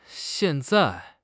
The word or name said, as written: xian4 zai4